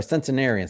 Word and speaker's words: centenarian: I write in the book talking about I'm going to be a Centurion podcaster. I can already picture that I'm wearing my shirt as a Centurion podcaster or Centenarian. Sorry, 100 years old. So I always say it wrong, like Centurion.